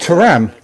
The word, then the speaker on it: Thuram: Am I not saying his name right? I'm terrible pronoun- pronouncing names. – Thuram, Lilian Thuram.